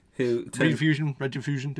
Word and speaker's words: Rediffusion: Is that how you say it? Rediffusion. – Mm-hmm. Rediffusion depends.